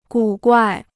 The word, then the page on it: 古怪: gǔ guài